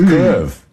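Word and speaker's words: Kyiv: Now, the Russian pronunciation is Kiev. The Ukrainian, closest approximation that we can do as non-Slavic speakers, is Kiev. So the Y ends at the first syllable.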